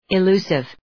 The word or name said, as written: illusive